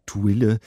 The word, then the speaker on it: Thuille: Schon der Name, finde ich, ist so ein kleines Mysterium, Ludwig Thuille. Habe ich ihn immer genannt. Ist das denn korrekt oder wird er französisch ausgesprochen? – Nein, er wird nicht französisch ausgesprochen.